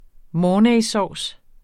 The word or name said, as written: mornaysauce